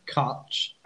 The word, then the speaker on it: Cwtch: Well, I'm on Tiny Rebel It's called, well, it's spelled C-W-T-C-H. It's pronounced Kutch, and it simply means a hug full of love.